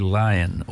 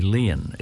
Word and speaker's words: Laighean: it's C-E-O-L-T-O-I-R-I, Kaltori, L-A-I-G-H-E-A-N. Kaltori Lion, or possibly Lian, L-A-I-G-H-E-A-N.